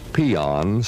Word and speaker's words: paean: In the dictionary, you will find a word pronounced peon, spelled P-A-E-A-N.